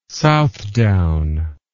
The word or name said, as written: Southdown